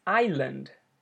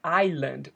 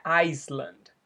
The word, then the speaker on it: island: Então, so, quer dizer, então, eu decidi ir, I decided to go to this island, para essa ilha. Muito importante aqui que a pronúncia dessa palavra é island. O S, ele é mudo, então não é Iceland, ok? É island, o S totalmente mudo.